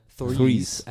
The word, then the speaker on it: Threes: Trees, das war jetzt sehr irisch ausgesprochen. Threes. Ein richtiges TH.